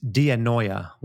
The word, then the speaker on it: dianoia: Yet there's a separate word for mind here, which is dianoia, which sort of pronounced like paranoia.